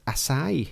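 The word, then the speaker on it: açaí: I think that's a soft C, if it's got an accent under it. Acai.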